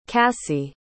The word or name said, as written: cassy